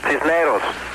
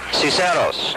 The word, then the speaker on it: Cisneros: Do you pronounce that Ciceros? – Cisneros. – Ciceros. – Cisneros. – Ciceros. – No.